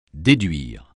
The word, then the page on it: déduire: French